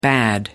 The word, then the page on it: bad: American English